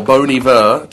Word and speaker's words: Bon Iver: It's Bon Iver, or Bon Iver, to give it the correct French pronunciation, which means good winter.